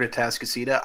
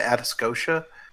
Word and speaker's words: Atascocita: Gerald, if it makes you feel any better, the first time I saw the word Atascocita, I called it Atascocia.